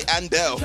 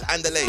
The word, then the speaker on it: Andale: It's spelled A-N-D-A-L-E. Say Andel, but it's Andele.